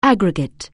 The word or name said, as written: aggregate